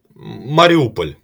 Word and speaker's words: Mariupol: I mean, on the one hand, like Ukraine, you know, outside of Maripol or however you say that. How how do you say the name of that city? Mariupol, Mariupol. It's yeah, Mariupol. – Well, you can just Mariupol. Mariupol. Mariupol, kind of like that.